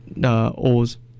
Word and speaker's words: OOO: Maybe I can choose Kamen Rider O's. There's this Kamen Rider, their name is like O-O-O. – Okay. – And they pronounce it as O's, like O's with an S.